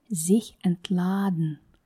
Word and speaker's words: sich entladen: Обратите внимание на то, что ударение падает на корень слова, так как приставка неотделяемая. И также гласное под ударением в корне слова произносится долго. Sich entladen.